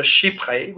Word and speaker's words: chypre: The next family is called a chypre, which is spelled C-H-Y-P-R-E with an accent acute.